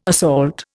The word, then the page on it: assault: Indian